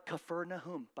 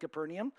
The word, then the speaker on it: Kfar Nahum: Uh, they call it Kfar Nahum, by the way. Side note if you ever say Kfar Nahum instead of Capernaum, everybody will think you're super smart because that's how evidently it's pronounced in Hebrew.